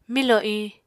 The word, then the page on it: milò iñ: Apatani